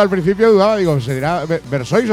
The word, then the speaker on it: Versoix: Y yo quería preguntarte también, esta ya pregunta mía, que tengo yo mucha curiosidad, porque bueno, yo estoy pronunciando bien el nombre de la banda, que es Versoix, ¿no? – Sí.